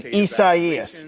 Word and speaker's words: Isaias: The governor getting the state of Florida ready for Hurricane Isaias.